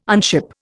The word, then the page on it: unship: Indian